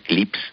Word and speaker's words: CLPS: Pues porque la NASA tiene un programa de exploración lunar comercial que se llama el programa CLPS, CLIPS, se pronuncia en inglés,